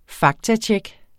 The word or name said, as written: faktatjek